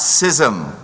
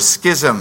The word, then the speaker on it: schism: But secondly, his response was a courageous and gracious separation, not schism or schism. You can pronounce it either way.